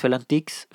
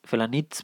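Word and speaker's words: Felanitx: trofeo Cesalins Felantix, Felanitz, perdón,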